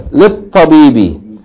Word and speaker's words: للطبيب: Yeah, because the L is not pronounced, right? Because the sun letter. للطبيب.